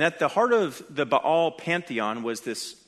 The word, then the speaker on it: Baal: Now, we're going to discuss him by name in just a few more verses, but it's worth spending some time here addressing Baal. Baal, you could say, if I want to save a syllable.